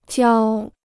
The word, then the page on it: 交: jiāo